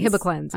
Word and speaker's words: Hibiclens: H-I-B-I? – Like hibiscus, but cleanse. – Okay, Hibiclens.